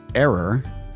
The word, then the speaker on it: err: Speaking of the first 3 letters of the word error, I took a look at the pronunciation of the word spelled E-R-R. I gave David credit for pronouncing it correctly as err, but I should point out that while David's is the more courageous choice, err is still considered acceptable.